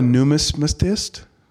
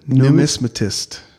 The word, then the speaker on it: numismatist: I'm a numismatist, so. – What's that? – A coin collector. – How do you say it? The numismatist.